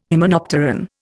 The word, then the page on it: hymenopteran: Indian